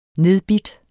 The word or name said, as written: nedbidt